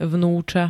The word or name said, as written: vnuca